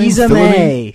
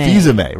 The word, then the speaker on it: Fils-Aimé: Fils-Aimé. Fils-Aimé. – Fils-Aimé, right.